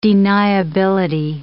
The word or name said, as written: deniability